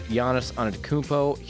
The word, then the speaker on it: Giannis Antetokounmpo: Giannis Antetokounmpo, yeah, I said that right.